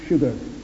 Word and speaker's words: sugar: George Bernard Shaw was once speaking in a public meeting, and in the course of his remarks, he said that there's only one word in the English language which is spelt S but pronounced SH, and that's sugar.